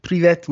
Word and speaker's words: privet: Privet. Dan. Hello. See, Daniel taught me a Russian word, which is privet, which means hi.